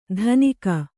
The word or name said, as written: ಧನಿಕ